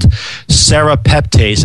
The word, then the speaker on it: serrapeptase: And that's called serrapeptase. And I'm going to spell that S-E-R-R-A-P-E-P-T-A-S-E.